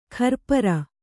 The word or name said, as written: ಕರಪುರಿ